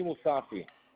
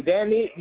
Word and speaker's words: Mosaffi: Danny Musafi. – Daniel. – Danny Musafi, MOSAFFI.